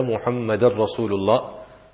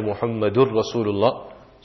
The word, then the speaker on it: Muhammadar Rasulullah: Muhammada will have a fatha. Some make the mistake and say anna Muhammadur Rasulullah. So it will have a fatha, it will not have a pesh or a dhamma.